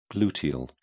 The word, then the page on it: gluteal: British English